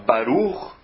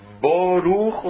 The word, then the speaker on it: Baruch: Se você fala Baruch, se você fala Boruch ou se você fala Burich, as consoantes são as mesmas, a pronúncia das consoantes está idêntica nas três formas.